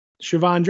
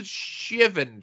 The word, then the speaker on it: Shivan: Siobhan Dragon. Tony Schiavone Dragon? – No. I always pronounce it Shivan Dragon or Shivan Dragon.